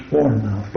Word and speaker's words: Bournemouth: It's pronounced Bournemouth. – Bournemouth. Okay. I knew I was going to get that wrong, but I appreciate your correcting me.